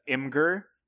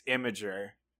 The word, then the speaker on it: Imgur: Also, that messed me up because I've always been pronouncing it as Imgur. Apparently, it's imager. So. – I think it's meant to be imager, but who knows how these wacky, you know, newfangled tech companies are spelled.